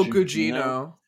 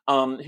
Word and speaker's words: Gugino: Oh, Gugino. Gugino.